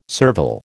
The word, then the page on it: servile: American English